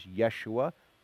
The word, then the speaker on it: Yeshua: Joshua, the actual Hebrew pronunciation is Yeshua.